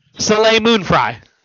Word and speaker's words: Soleil Moon Frye: Soleil Moon Frye.